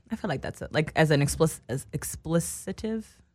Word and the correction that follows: expletive: Expletive. – Expletive. Is that how you say it? – Expletive.